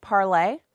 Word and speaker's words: Parler: Sorry, it's pronounced Parlay, but it's Parler News. And it's a new platform. It's named for the French word meaning to speak, Parlay.